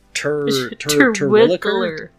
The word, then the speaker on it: Terwilliger: Terwilliger. – You gotta think Dr. Seuss. – Terwilliger.